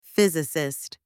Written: physicist

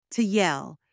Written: to yell

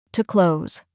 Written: to close

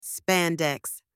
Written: spandex

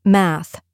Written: math

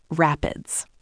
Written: rapids

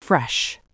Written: fresh

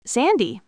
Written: sandy

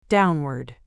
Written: downward

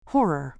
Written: horror

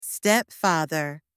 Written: stepfather

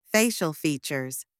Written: facial features

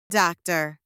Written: doctor

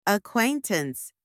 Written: acquaintance

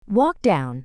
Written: walk down

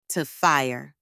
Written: to fire

